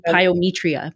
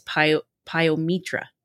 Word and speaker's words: pyometra: I've always said pyometria, but it's pyometra.